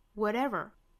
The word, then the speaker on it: whatever: impossible 这个啊 whatever, 中间的这个 t 的发音需要发成一个 flap t, 发成 whatever, whatever, 后面 improbable 里面的元音 pro, a, a, improbable, 其他的就跟音都一样了。